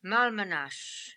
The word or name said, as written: Malmanash